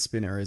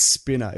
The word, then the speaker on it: Spiner: And it's Spiner. If we're getting into pronunciation, it's Spiner. – It's definitely not. That's Spinner. – No, it's not. The two N's is Spinner. – Yeah, that's how you spell it. Let's look it up. – Brent Spiner. Data from Star Trek. – Yeah, I know. – It's Spiner. One N.